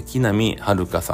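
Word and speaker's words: 木南晴夏: 木に、南に、晴れるに、夏と書いて木南遥さんですね。えー、っと、知ってるかなえー、さっき言った安藤サクラちゃんのブラッシュアップライフで、安藤サクラちゃん、木南遥さん、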